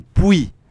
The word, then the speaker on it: Pouy: Le village, c'est Puy. Ça s'écrit P-O-U-Y.